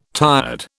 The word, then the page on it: tired: British English